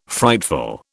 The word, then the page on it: frightful: British English